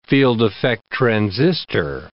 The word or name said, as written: field-effect transistor